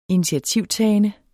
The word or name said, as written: initiativtagende